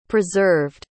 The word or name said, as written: preserved